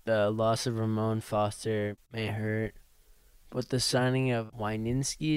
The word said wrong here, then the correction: Wisniewski: is that how you pronounce it? – Wisniewski. – Wisniewski.